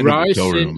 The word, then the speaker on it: ricin: We had somebody who was making ricin. I believe that's how it's pronounced in, in one of the ricin. Yeah, ricin is in place in the book.